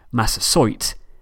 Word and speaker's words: Massasoit: The chief who was allied to Plymouth and who journeyed there for Thanksgiving should be pronounced Massasoit, and his tribe should be pronounced Wampanoag.